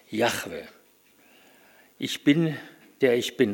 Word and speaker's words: Yahweh: Yahweh, Yahweh, so wird das ausgedrückt dort.